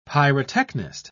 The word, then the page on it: pyrotechnist: British English